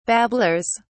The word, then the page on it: babblers: British English